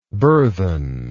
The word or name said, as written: burthensome